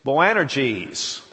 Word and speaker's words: Boanerges: And it's Boanerges, that's how you pronounce it.